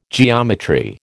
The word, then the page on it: geometry: American English